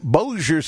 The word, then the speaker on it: Bossier: Bossier. – It looks like Bossier. – You're too franchising it. It's more Americanized. – I'm too franchising? – Yeah. – All right. Gene in Bossier City, Louisiana, listens on 710 Keel.